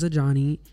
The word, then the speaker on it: Ajani: So like I said, my name's Ajani. It's spelled A-J-A-N-I.